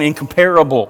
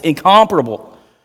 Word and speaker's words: incomparable: For those of you that pronounce that wrong, incomparable, right? It's incomparable.